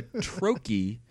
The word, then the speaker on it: troche: And a troche is a two syllable word or series of one syllables. That has a stress that actually sounds like the word troche. So it's that first stressed syllable and then an unstressed syllable.